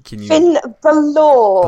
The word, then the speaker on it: Finn Balor: Finn Balor.